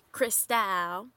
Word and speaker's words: Cristal: And then she gets a call from her friend, Johnny May, who is Cristal, which I'm going to say Cristal. – I, too, pronounce it that way as I read the script. So, yes. – Like the alcohol.